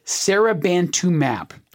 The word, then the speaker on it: Seribantumab: Seribantumab. And I'm emphasizing that last syllable, mab, because M-A-B, when you see it spelled out, that is a suffix at the very end of the drug name.